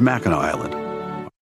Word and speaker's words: Mackinac Island: It's, there it is, Mackinac Island, although it's spelled Mackinac for some reason.